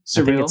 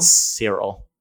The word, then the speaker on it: Syril: Uh, how do you how do you, how do you say his name? – Syril. – Syril.